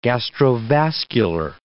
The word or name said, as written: gastrovascular